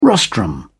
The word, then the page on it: rostrum: British English